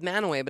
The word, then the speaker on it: Mantua: No, it's called Manaway, but it's spelled like Mantua.